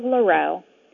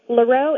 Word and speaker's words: Leroux: Hi, my name is Cara Leroux. Leroux is a French name, and it rhymes with other French words like bureau and chateau and plateau.